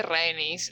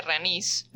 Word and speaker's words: Rhaenys: Bueno, yo le dije Renis el capítulo pasado, pero lo pronuncia Ranis Lo pronuncia Ranis en la serie.